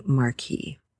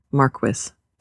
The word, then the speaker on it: marquis: And that is the name for the wife or the widow of a marquis or marquis, depending on where you're getting your pronunciation from.